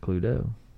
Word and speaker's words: Cluedo: In England, where musician Anthony Pratt invented the game in 1944, it's called Cluedo, pronounced Cluedo, meaning clue, and the Latin term ludo, meaning play.